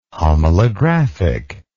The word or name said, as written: homalographic